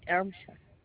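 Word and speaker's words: Ermsher: Maria, and last name Ermsha. – How do you spell that, the last name? – That's E-R-M-S-H-E-R.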